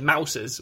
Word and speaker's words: mouses: They said mouses, which I think they rhymed with houses, and then they said meese.